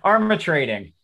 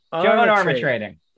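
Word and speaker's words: Armatrading: Armitrading. Armitrading. – Love and affection. I just love that song. Do you love that song, Ben? – I had not heard that song. I do know Joan Armitrading, but I did not know that song.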